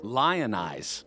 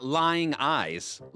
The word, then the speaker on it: lionize: Not lying eyes. Lionize. – Lionize. For those following along at home, that's L-I-O-N-I-Z-E. It's a verb. – It's what you do. – Right. Three syllables. Lionize.